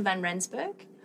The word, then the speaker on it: van Rensburg: So I'm Sabine van Rensburg. It's a Dutch origin, so that's why it's a little complicated to say.